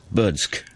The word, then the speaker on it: Berdsk: It's the first overseas tournament for this team called Burtsk. I pronounced that correctly, I hope. Smith's much easier. B-E-R-D-S-K. How does that sound? Burtsk? – Burtsk.